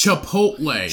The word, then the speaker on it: Chipotle: Like chip. Like chocolate chip. Chipotle.